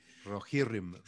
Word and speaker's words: Rohirrim: Rohirrim, Rohirrim se dice.